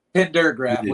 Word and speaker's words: Pendergrav: Pendergrav with a D.